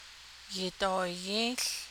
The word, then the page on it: yidooyį́į́ł: Navajo